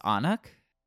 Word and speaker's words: Annag: But the one that I saw the most was Anak, which is A-N-N-A-G. It's like Gaelic Anna, basically. But oh. There's just kind of like a sound at the end of it.